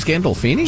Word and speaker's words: Gandolfini: Gallofini? I don't remember his last name. – I don't know. Oh, James Gandolfini? – James Gandolfini, yeah.